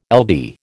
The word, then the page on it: LD: American English